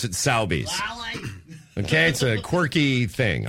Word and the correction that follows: Sobeys: I just mispronounced it. I just fucked up the pronunciation of Sobeys.